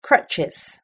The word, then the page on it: crutches: British English